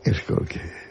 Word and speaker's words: Skolsky: No, señor, no es SH, es SKOLSKY. – Impronunciable. – A la señora. – ¿Cómo se pronuncia? Porque es polaco. ¿Cómo se pronunciaría en polaco Skolsky? – Skolsky. Como tú lo has dicho.